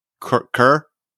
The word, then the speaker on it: Cuir: It's spelled C U I R. Kerr. Dan. Cur. All right. Which I assumed was like a cur, like a dog in English, but it actually translates to leather, which is interesting because I don't know if you guys have heard this band yet, but it's pretty cool.